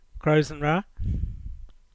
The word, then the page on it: Crows-an-Wra: English